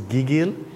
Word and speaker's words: gigil: Yes, the Tagalog word for suddenly seized by affection is gigil. – Spell it. – G-I-G-I-L. Gigil. – And pronounce the second syllable, emphasize. – It's the first gigil. Gigil. No, it's the first syllable.